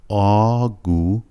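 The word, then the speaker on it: آگورت (огурец): ولی اگوریت نیست، فشار روی قسمت اول نیست، اگوریت غلطه، آگورت درسته، رتسشش که فشار میاریم روش.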